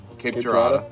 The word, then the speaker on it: Cape Girardeau: Cape Girardeau.